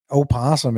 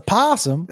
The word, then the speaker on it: opossum: But that's not right. Opossum isn't correct, is it? – It was an opossum. – It's a possum. – It's spelled opossum.